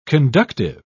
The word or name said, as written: conductive